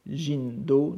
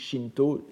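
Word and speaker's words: Jindo (神道): Jindo, n'est-ce pas, à prononcer la bouddhique, Shinto, Jindo, auprès de Butsudo.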